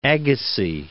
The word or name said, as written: Agassiz